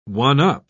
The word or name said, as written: one-up